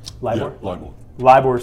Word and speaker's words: LIBOR: LIBOR scandal.